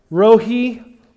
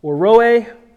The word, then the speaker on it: Rohi: This morning we come to Yahweh, Rohi, or Roe. It's again pronounced many different ways, as in many of the Hebrew names. It has to do with our English language and trying to spell out syllables that we do not have, that are not native to our tongue. I will refer to it as Yahweh, Roe.